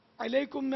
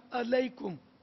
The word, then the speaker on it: علیکم: اور علیکم میں عین ہے، حلق سے نکلے گا، الف سے نکالا علیکم، تب بھی محمل ہو گیا، سلام نہیں ہوا نا.